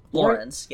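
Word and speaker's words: Laurens: Is it Lorenz? – Lorenz, yeah.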